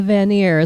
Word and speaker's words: Vanir: A Vanir is how it's pronounced.